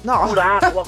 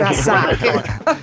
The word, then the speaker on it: Curaçao: Sì, Capo Verde, Curaçao, Curacqua. Come si chiamano queste squadre? – Curaçao.